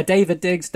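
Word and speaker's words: Daveed Diggs: David Diggs. Is that his name? Did I get his name right? – Yeah, David Diggs, David, yeah. D-A-V-E-E-D, right?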